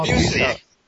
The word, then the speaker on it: Busiek: For Eric, we have Kurt, how do you say this? Bursnick? – Busiek? – Oh, Busiek. – Yeah, there we go.